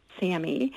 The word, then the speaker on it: SAMe: I mean, it's actually S-adenosylmethionine, but it's pronounced SAMe.